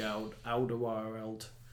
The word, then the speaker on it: Alderweireld: And you mentioned obviously the Toby Alderweireld.